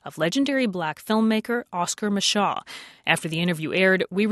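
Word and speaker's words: Micheaux: Mr. England wrote us, and he says, "I often hear Oscar Mashaw's name pronounced up to four ways." In a 1981 documentary that included interviews with actors Bee Freeman and Lorenzo Tucker, both pronounced the name Mashaw sometimes, but more often Mashaw.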